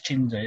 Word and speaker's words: Tshiunza: you've got uh, Chris, uh, I can never pronounce his Tshiunza. Chris, Chris Tshiunza, yeah.